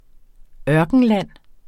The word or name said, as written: ørkenland